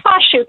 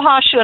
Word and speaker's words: Hoshu: I'm sorry, Carol, if I got your last name wrong, because I can hardly speak my own native tongue, English, most days. Well, how do I pronounce your name? – It's Hoshu, Carol Hoshu, and it's a pleasure to join you and your listeners today, Tim.